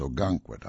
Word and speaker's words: Ogunquit: And by the way, the town is Ogunquit. I had mispronounced it earlier, just so you know.